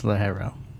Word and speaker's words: Ligero: It's Ligero. – That G gives you a silent H.